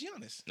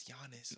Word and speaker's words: Giannis: You're not even saying his first name right. – His name is Giannis. – No, it's Giannis. – Oh, it's Giannis. Yeah.